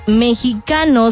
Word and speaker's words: mexicano: Pues resulta que en algunas regiones la X de México pues se pronuncia como sh. Entonces, mexicano sonaba más o menos como mexicano.